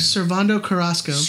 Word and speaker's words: Servando Carrasco: How do I say this guy's name? – Servando Carrasco. Just say Mr. Alex Morgan.